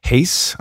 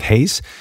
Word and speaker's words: Hayes: Well, today's guest, Kat Hayes, I should pronounce that carefully because Kat, Kat's last name is, it sounds like mine, it's, it's spelt differently, Kat Hayes.